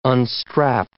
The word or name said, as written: unstrap